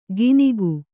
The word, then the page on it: ಗಿನಿಗು: Kannada